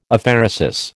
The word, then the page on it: Aphaeresis: American English